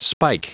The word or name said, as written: spike